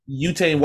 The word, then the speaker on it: Yuta: Yuta Watanabe, who was leading the league in 3-point percentage for long stretches of the NBA season, only played 4 minutes.